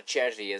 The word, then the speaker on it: Cervi: Okay, a couple of Argentinian um, Argentinian announcers and were they happy to see Servi on the field or Cervi as as he, you know it's pronounced in Spanish or or in Italian as the name is actually an Italian name.